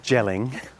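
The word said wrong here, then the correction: Jelling: Gelling. Dad, yelling. It's yelling, Dad. – Stop. What do you mean? It's gelling. It's pronounced, it's got a J on it. – But that's not normal. You say Y for Germanic languages.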